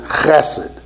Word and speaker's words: chesed: I tried to pronounce it right, and I can't get it. It's chesed, right? There's that with it. I mean, that there's an accent, right, to that chesed.